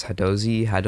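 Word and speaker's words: Hadozee: There's the, I don't know how to pronounce this, Hadozi? – I think Hadozi's right.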